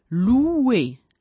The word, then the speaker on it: louer: O più U, louer, louer, affittare o lodare secondo il contesto.